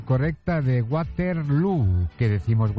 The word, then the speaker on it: Waterloo: Pues no lo es, desde luego, pero ha hecho mucho daño en la pronunciación de Waterloo, que se pronuncia igual que Feijóo.